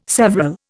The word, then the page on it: several: Indian